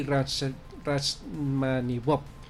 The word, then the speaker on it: Rachmaninoff: Y bueno, nada, la invitación para nuestros oyentes es para que asistan al concierto especial Redden Bus de Rimsky-Korsakov, Kim y Rachmaninoff.